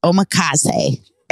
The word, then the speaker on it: omakase: Guys, the newest trend in food is omakase. Did I say it right? – Yeah, that.